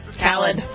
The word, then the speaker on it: Khalid: DJ Khalid. That's how you pronounce his name, right? – Khalid. – Khalid. – Khalid? – Mm-hmm. – Yeah, okay. I like Khalid.